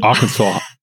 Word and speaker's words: Arkansas: Oh, wie spricht man das? – Arkansas. – Arkansas. Arkansas.